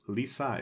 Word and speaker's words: Lise: Sorry, it's Lisa is how they keep pronouncing it. I'm reading it because it's spelled L-I-S-E, the French spelling.